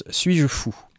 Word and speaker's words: S8JFOU: Et si, et vous sur, Google ou, votre moteur de recherche, c'est S8JFOU. S8JFOU et ça se prononce Suis-je fou C'est cool.